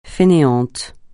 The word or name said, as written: fainéant